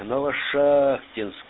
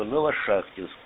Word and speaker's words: Новошахтинск: И вот почему-то всегда я говорил Новошахтинск. Но вот у меня тут есть товарищ такой грамотный. И он подсказал мне, что надо ударение делать на А. Новошахтинск. Ну вот я с тех пор и говорю, что Новошахтинск.